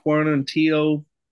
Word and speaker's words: Quarantillo: We got that boy Edson Barboza versus, how do you say his last name? – Billy Quarantillo, we just call him Billy Q over here, 813 product, South Gracie, Tampa.